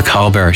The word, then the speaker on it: Colbert: And I wonder, Robert Plant recently did one of their songs, Ramble On, on the Colbert Show. Yes, I've heard how he pronounces his whole name. It's the T that's silent.